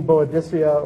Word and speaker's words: Boadicea: Another set of characters that I like to think about are, this is Augustus and Queen Boadicea, or Boudica, depending on how you pronounce it.